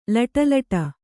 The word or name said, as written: ಲೊಡಲೊಡ